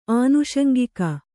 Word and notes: ಆನುಷಂಗಿಕ: ānuṣaŋgika

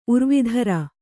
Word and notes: ಊರ್ವೀಧರ: ūrvīdhara

